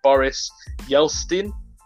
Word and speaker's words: Boris Yeltsin: So you've got the Russian president at the time, if you bear with me on pronunciation, I believe his name is Boris Yeltsin.